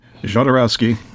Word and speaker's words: Jodorowsky: Jodorowsky.